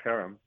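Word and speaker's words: herem: And is it pronounced a harem? – Harem. You have to put a little more throat in there.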